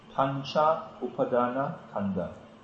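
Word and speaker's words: Pancha Upadana Khandha: Panchupadana, Khanda. – Form, view, perception, volition, formation, and processes. – Let me explain that. – It's actually Pancha Upadana Khanda.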